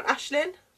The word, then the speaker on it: Aisling: It will probably be either Louisa or Charlotte, and that's Eva Bell and Aislinn. I can never pronounce the name when I see it wrote down. Aislinn. – Is it A-I-S-L-I-N-G? – Yeah, Aislinn. Loftus?